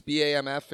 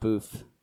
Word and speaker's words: BAMF: You can pronounce it however you want, but that's how it's spelled. And I like pronouncing it boof. – That's definitely not how.